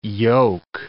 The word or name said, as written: yoke